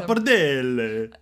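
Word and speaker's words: pappardelle: Papardelle? – Czemu powiedziałeś to z jakimś świńskim akcentem? – Papardelle. – Dobra, teraz lepiej.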